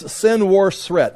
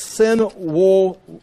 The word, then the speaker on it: Senwosret: This is Senwosret the second. Again, it also can be pronounced Senuseret the second.